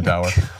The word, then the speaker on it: dour: Actually, the correct pronunciation of this word is door, but nobody pronounces it that way, so I'll just say dour.